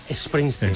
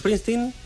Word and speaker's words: Springsteen: Sí, bueno, nosotros es que la S, la, claro, pronunciamos la E delante, ¿no? – Claro, nos decimos Bruce Springsteen.